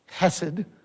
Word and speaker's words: hesed: It's hesed. If you cough up a hairball when you say it, you're pronouncing it right.